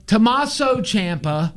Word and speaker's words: Tommaso Ciampa: Thomas Ciampa. – Tommaso Ciampa. – Thomas. You don't call him Thomas? – It's Tommaso. – He's going to come get me for saying his name wrong.